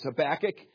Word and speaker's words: Habakkuk: I don't know about you, but I love the little book called Habakkuk in the Bible. A lot of people don't understand that book Habakkuk. First of all, they have struggle maybe even just pronouncing the name, but it's Habakkuk.